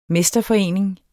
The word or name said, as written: mesterforening